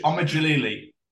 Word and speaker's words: Djalili: I'm going to pronounce it wrong again. Omid Djali? – Omid Djalili. Yeah, see, I wasn't going to get that right if my fucking life depended on it.